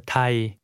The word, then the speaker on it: ไทย: ภาษาไทยไทย Thai is pronounced with a mid-level tone. Listen and repeat again.